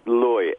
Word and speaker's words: Leu: Okay, it's Leu, L-E-U. It's the same E-U as Freud or Deutsch. It's the German way of pronouncing okay.